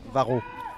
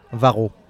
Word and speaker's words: Vigneault: Can you tell me, how do you pronounce that name in immaculate Varro. French? – Vigneault. – Cedric? Vigneault.